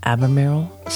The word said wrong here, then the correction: Albemarle: Albemarle. I think it's Albemarle. But it often gets an R in there and people misspell it. But there's only one R in Albemarle.